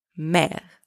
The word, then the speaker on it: mère: Mère spelled M-E-R means seam. So in these three words, the letter M is pronounced M, the letters A and I together, and also the letter E with a grave accent, and the letter E from the third word are all pronounced E.